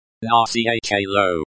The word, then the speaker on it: Начало: Начало. Stress on the second syllable.